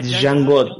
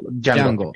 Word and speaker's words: Django: Eh, ah, ah, Django, Django.